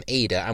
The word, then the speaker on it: Idah: I should point out that I think we've all made the mistake already, haven't we? Idah. As we have learned today. Adam Idah is how it should be. I've got to get used to it not being Idah. It's spelled I-D-A-H, but we should be calling him Adam Idah,